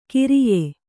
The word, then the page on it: ಕಿರಿಯೆ: kiriye